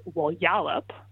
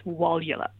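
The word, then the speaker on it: Walyalup: So it's Wallyalup, and the first syllable is the one that gets the stress. So by stress, I mean it's the one that's pronounced the loudest and the most emphatically. So it's not something like, say, Wallyalup, rather it's Wallyalup.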